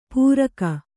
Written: ಪೂರಕ

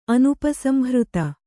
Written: ಅನುಪಸಂಹೃತ

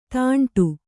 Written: ತುಂಟ

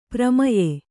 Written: ಪ್ರಮೇಯ

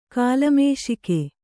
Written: ಕಿಲ್ಮಿಷಿಕ

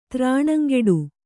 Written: ತ್ರಾಣಂಗೆಡು

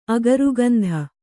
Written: ಅಗರುಗಂಧ

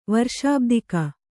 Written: ವರ್ಷಾಬ್ದಿಕ

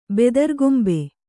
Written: ಬೆದರ್ಗೊಂಬೆ

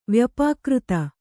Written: ವ್ಯಪಾಕೃತ

